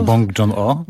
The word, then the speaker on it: Bong Joon-ho: No myślę, że ta litania, którą teraz wygłosiłeś, ona dobrze oddaje ducha tego filmu, czyli ducha dość szalonego, bo Juno Bong, tak chyba się wymawia jego nazwisko? – Bong Joon-ho.